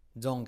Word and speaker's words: don't: Don't get me wrong. Don't get me wrong. Don't, la T desaparece de don't. Don't get.